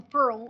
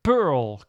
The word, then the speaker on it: Perl: Je leidt daar een groep, de Perl-groep, zeg ik dat goed? – Wij zeggen meestal Pearl op z'n Engels. – Pearl, kijk, van de brillen.